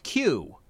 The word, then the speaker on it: CUE: or CUE, pronounced Q, just like in English.